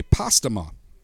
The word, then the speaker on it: Pastuma: It's spelled P-A-S-T-U-M-A, but I was told to say Postuma, like it was pasta and ma.